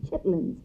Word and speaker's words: chitterlings: People call them chitlins, but it's chitterlings. That's the way it's spelled.